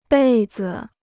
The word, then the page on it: 被子: bèi zi